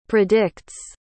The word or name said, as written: predicts